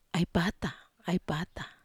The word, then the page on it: ai pata: Wamesa